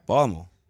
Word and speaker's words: Baltimore: There's an R in there. – Baltimore. – There you go.